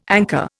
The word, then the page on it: anchor: Indian